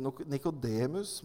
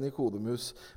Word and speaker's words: Nikodemus: Dette er en sånn uttale ting, altså hvordan skal man uttale navnet på Nikodemus? Der er det litt forskjellig syn på den saken. Mange sier Nikodemus, mens noen da lener seg til Nikodemus.